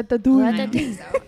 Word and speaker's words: Ratatouille: Because the correct French way of saying is Ratatouille. – That is a flawless accent. – Yeah. Ratatouille. – Americans say Ratatouille.